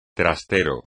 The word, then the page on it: trastero: espagnol